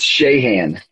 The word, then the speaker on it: Shahan: It's Shahan.